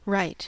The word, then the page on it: right: American English